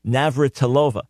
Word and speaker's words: Navratilova: Then you have the great tennis player, Martina Navratilova. I know the name. I keep mispronouncing it because I'm thinking about it before I say it. Navratilova.